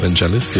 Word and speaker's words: Vangelis: Vangelis, yeah.